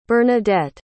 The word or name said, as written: bernadette